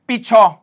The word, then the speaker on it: 比丘: 经里面就为世尊拜初呢、这个， sorry 唔系拜初，必初，必初系比丘嘅意思，拜初用咗咧另外一个发音啊，日文嗰个发音，